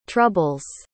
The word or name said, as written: troubles